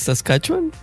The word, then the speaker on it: Saskatchewan: Saskatchewan, Saskatchewan. Muy cercano, muy cercano, muy bien. Vi lo que trataste de hacer, A ver, ¿cómo se dice tú? – Bueno, yo digo que es Saskatchewan. Saskatchewan.